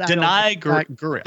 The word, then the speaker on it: Danai Gurira: I think it's Danai Gurira, but Danai Gurira.